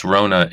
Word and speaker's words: Rona: Jess Rona is with us today.